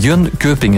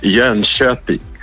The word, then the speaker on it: Jönköping: Het is niet Jönköping, hè? Dat is totaal verkeerd. Hè? Wat moeten we dan wel zeggen? – Ja, het is eigenlijk Jönköping. Jönköping, dus de K voor de omlaut wordt heel zacht uitgesproken in Zweden.